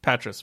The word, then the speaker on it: Poitras: How do you pronounce Patras. it? – Patras. Patras. Matt Patras.